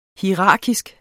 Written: hierarkisk

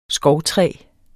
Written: skovtræ

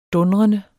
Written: dundrende

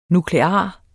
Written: nuklear

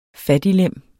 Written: fattiglem

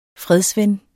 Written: fredsven

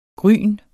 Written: grynet

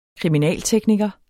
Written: kriminaltekniker

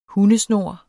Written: hundesnor

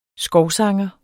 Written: skovsanger

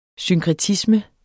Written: synkretisme

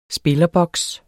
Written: spillerboks

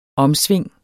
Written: omsving

